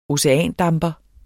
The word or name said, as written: oceandamper